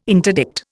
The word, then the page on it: interdict: Indian